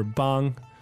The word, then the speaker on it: 帮: So one thing we should talk about here, though, is the pronunciation for bang. – Ah, that's right. – Because as we said, it's B-A-N-G, first tone.